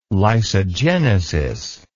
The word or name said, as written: lysogenesis